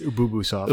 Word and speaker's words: Ubisoft: I don't know how it's pronounced. There's everyone on stage pronounces something a little bit differently, so I was kind of confused. – It's Ubisoft.